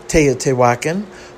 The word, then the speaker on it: Teotihuacan: If you saw the way this word is spelled, you would never come up with Teotihuacan,